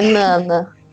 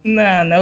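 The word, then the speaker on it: Nanna: Porque eu sei que a, a Nana, Nana, Naná, como é que fala? Quem, quem é o um especialista aí nos nomes? É Nana. Nana, ok.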